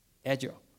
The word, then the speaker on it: Azure: 好，这边的话，如果你是使用 Azure 的话，你在这一步的话，你会租用一个 Azure。好，哎，对不起，这边稍微忘记问一个。通常我被问的第一个问题的话，就是这一个。怎么发音？Azure，对，其实最简单好像就记得星光三月有 A7，有 A8，有 A9，好，就是 A9。